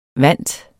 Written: VANTERNES

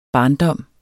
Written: barndom

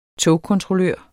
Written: togkontrollør

